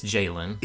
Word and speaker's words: Jalen: You said it right. It's Jalen. That's how to pronounce it.